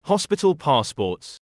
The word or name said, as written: hospital passports